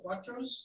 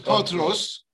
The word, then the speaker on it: potros: Are you saying Cuatros? – No, it's Potros.